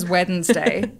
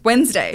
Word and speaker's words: Wednesday: We love you, Hannah, but yeah, she says Wednesday. And I'm like, no, it's Wednesday. – It's Wednesday. – It's Wednesday. – Yeah. The but D is silent.